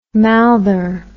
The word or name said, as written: mouther